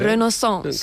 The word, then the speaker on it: Renaissance: Renaissance.